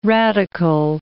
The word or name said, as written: radical